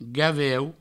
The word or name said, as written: gaveu